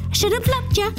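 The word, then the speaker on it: Shoulda Loved Ya: And I should have loved you. Actually, have to say it like this. Should have loved ya.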